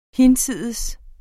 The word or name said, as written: hinsides